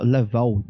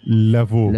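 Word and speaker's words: Lavaud: É o Dimitri Sensaud de La, Laval, Laval, não sei como é que fala o nome do cara aqui, vou... Lavou, Lavou. – provavelmente. É, L-A-V-A-U-D.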